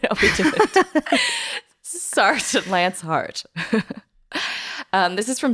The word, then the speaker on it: Sartre: How do you say it? – Sartre? – No Sartre? Exit by, that's who right? – Jean-Paul, right? – Jean-Paul Sartre. Sartre. It's Sartre.